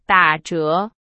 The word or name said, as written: 打折